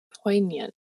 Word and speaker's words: poignant: Poignant. That's how you say it.